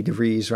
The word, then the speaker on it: DeVries: We we may have uh, we may have when we were talking to Vicky DeVries rhymes with cheese.